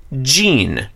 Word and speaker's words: Jin: In which case, John, this name should be pronounced Jean. – Mm. – We would romanize it J-I-N to get today.